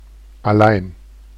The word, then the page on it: allein: /a.ˈlaɪn/